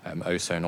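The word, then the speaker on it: Ausone: Osohn, always slightly tricky on the Asian market because the AU is a sort of difficult thing to pronounce, but Lafite Latour do very well there.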